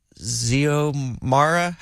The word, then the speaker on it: Xiomara: Can you say happy birthday to Xiomara. How would you pronounce this? X-I-O-M-A-R-A.